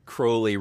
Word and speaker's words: Crowley: I think all the magic people will say Crowley rhymes with holy because I think that's the thing he liked to say.